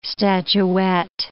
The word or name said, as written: statuette